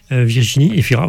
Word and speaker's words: Virginie Efira: Rappelez-vous ce film où Virginia Efira... – Virginie Efira, oui. – Vous prononcez à l'italienne.